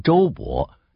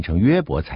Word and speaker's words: 约伯: 英文把约伯翻译成周伯，但是希伯来文的发音是约而不是周，所以应该念成约伯才对。